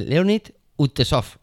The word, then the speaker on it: Leonid Utesov: Leonid Utesov.